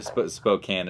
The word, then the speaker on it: Spokane: Yeah, I don't think it rhymes with cocaine. I'm pretty sure Spokane is right.